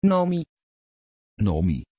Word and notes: Nomi: Dutch

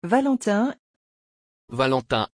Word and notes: valentín: French

